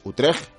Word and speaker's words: Utrecht: Teníamos a Luke aclarándonos cómo se pronuncia en neerlandés Utrecht que debe ser así, como con esa J al final.